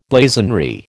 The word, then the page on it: blazonry: American English